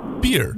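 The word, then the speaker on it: Bier: This is the German word for beer, which is, funnily enough, pronounced beer.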